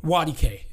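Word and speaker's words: Nwadike: The N is silent. – Wadike. – Wadike, yeah. So if you pronounce it phonetically, it would sound like wa, like W-A, and then just the letter D, and then K-A-Y is how it would sound. That's Wadike. Easy.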